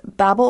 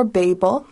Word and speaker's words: Babel: Now, the name of this album is Babel or Babel. You hear it pronounced both ways.